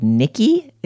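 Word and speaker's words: Knickey: Until now, when I have discovered a brand called Nicky. It's spelled like Knickers, but E-Y, K-N-I-C-K-E-Y.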